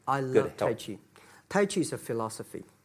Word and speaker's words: Tai Chi: Am I saying that right? A trainer. – Trainer. – Tai Chi? – Tai Chi. – Tai Chi. – Tai Chi, yeah.